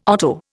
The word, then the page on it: otto: Indian